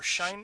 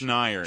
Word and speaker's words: Schneier: Is it Schneier or Shine? – It's Schneier. It's I can spell it.